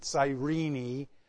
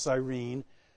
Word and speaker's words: Cyrene: Some pronounce it Cyrene, but it's usually pronounced Cyrene.